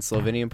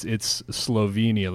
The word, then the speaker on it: Slovenia: Italian is co-official with Slovene in three municipalities in Slovenian portion of Istria. – There you go. Okay, yeah. And just so everybody knows, it's Slovenia. Like, I hear people saying, like Slovenia, like it rhymes with Pennsylvania.